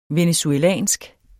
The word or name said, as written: venezuelsk